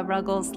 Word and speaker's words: Ruggles: I always tell people I pronounce it Lara like Sarah, Ruggles like Snuggles.